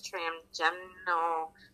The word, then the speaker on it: trigeminal: I can never say it correctly. It's trigeminal facial nerve.